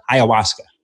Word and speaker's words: ayahuasca: Ashwagandha? Ayahuasca? Ayahuasca. That's it.